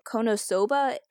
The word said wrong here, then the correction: Konosuba: K O N O S U B A.